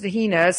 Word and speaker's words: Hina: I say it pronounced Hina. People may call it Hina, H-I-N-A.